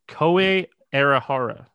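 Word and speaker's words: Kohei Arihara: Koei Arihara. I was close. Is how it's pronounced on a broadcast.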